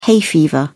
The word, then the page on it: hay fever: British English